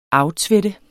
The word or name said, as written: aftvætte